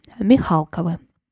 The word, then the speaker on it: Михалковы: Кстати, иногда в этом дворянском роду можно найти ударение на «а» – Михалковы.